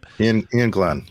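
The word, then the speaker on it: Iain Glen: Ian Glenn. – Yeah. – Or Lane Glenn, or how do you say his name? – Ian Glenn. Yeah.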